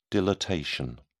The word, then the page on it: dilatation: British English